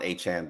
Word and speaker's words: Achane: Get Devon Achane back. Did I say it right? – Yes, you did.